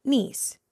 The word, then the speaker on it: Nice: Is that how you say it? Nice, right? Nice. Yes.